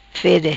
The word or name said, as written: fede